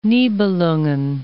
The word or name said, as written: Nibelungen